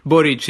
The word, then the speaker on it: Boric: I don't know if I'm saying his name right. He actually Boric, yeah. So it's actually a Croatian name. His family's of Croatian descent.